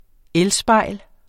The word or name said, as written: elspejl